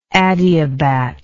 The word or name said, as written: adiabat